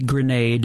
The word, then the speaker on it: Granade: It's I'm Charlie Grenade. It's spelled G-R-A-N-A-D-E.